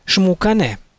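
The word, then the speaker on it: Xmucane: Говоря о сотворении мира, книга Пополь Вух называет прародителей мира именами Шпиякок и Шмукане. Отметим для дальнейшего, что начальная буква X в определенных словах киче и в начале собственных имен произносится как ше.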